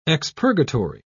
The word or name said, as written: expurgatory